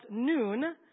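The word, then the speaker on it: Noon (نون): That is pronounced Noon, and it stands for Nazara, or means that this person is a follower of Jesus of Nazareth.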